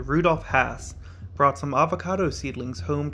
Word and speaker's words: Hass: And while I'm not an expert in avocado names, I do pronounce the avocado Hass, H-A-S-S, as Hass. According to the Hass Avocado Naming Foundation, the name rhymes with pass. Typically, I would have thought that it was pronounced Haas, but that is incorrect.